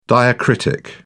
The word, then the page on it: diacritic: British English